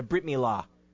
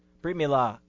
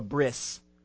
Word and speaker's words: Brit Milah: It's called a Brit Milah. Brit Milah. You might hear it called a bris, or that's an Ashkenazic pronunciation.